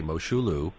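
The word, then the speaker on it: Mosholu: Well, this is another one of those places that has a number of pronunciations. Many people say Moshaloo.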